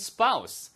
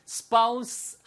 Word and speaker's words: spouse: 예, 그래서 How did you meet your current spouse? 발음은 두 가지예요. Spouse 하셔도 되고요. Spouse 하셔도 돼요. 아시겠죠? 근데 spouse는 아니에요.